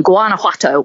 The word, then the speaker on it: Guanajuato: Guanajuato on All Live, yeah.